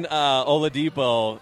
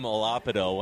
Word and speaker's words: Oladipo: I was on Oladipo so early, I didn't even know how to say his name, and I called him Olapido one time.